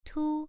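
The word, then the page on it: tu: tone 1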